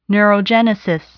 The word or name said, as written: neurogenesis